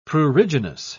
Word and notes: pruriginous: British English

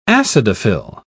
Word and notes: acidophile: British English

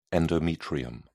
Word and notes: endometrium: British English

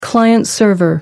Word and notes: client-server: American English

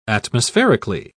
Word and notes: atmospherically: British English

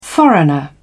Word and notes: foreigner: British English

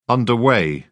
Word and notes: underway: British English

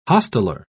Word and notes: hosteler: British English

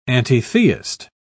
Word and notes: antitheist: British English

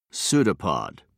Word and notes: pseudopod: British English

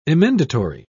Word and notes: emendatory: British English

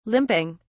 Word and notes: limping: British English

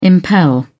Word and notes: impel: British English

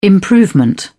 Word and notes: improvement: British English

